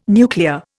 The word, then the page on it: nuclear: Indian